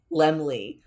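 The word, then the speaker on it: Laemmle: Lemley.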